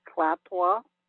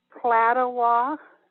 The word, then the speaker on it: Klatawa: I think we really should add Klaptwa. – How do you spell that? – K-L-A-T-A-W-A, and you can put an H on the end if you want, because it's an aw sound.